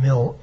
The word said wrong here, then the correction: Milne: It's Milne.